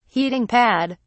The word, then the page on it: heating pad: British English